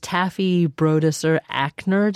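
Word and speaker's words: Taffy Brodesser-Akner: And that's the author is Taffy Brodesser Ackner. Did I say her name? – Correct.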